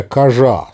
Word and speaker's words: кожа: «Черная кожа» – слово это произносилось обязательно с ударением на последний слог.